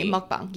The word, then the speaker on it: mukbang: We're talking about the word that's spelled mukbang. – Mukbang, yes.